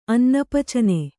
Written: ಅನ್ನಪಚನೆ

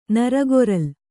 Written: ನರಗೊರಲ್

